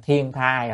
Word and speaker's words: thiên thai: Thử giữ thiên thai, chữ này có khi đọc là đài nhưng mà chúng ta quen là thiên thai rồi đúng không?